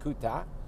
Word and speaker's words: Kuta: We stayed in Kuta, which is spelled K-U-T-U, I believe, but it's pronounced Kuta.